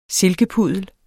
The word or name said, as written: silkepuddel